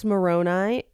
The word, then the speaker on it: Moroni: Now, I've always heard it pronounced Moroni, M-O-R-O-N-E, but it's definitely spelled Moroni.